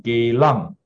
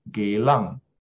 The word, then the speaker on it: Geylang: Last time, if you ask me where do I stay, then I will say Geylang, and say it very properly, Geylang. The correct pronunciation is Geylang.